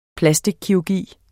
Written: plastikkirurgi

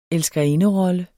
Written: elskerinderolle

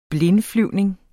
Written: blindflyvning

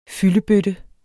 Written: fyldebøtte

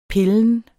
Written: pillen

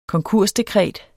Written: konkursdekret

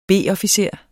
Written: B-officer

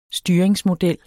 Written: Styringsmodel

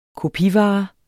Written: kopivare

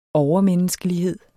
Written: overmenneskelighed

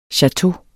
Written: chateau

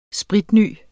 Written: spritny

